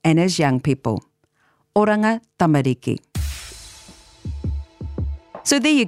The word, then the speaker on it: tamariki: The elongated sound I mentioned tends to creep in at tamariki, making it become tamariki. But there's no long sound, just the same emphasis for all of the syllables. So, tamariki. Oranga tamariki. That's the correct pronunciation.